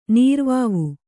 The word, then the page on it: ನೀರುವಾವು: nīruvāvu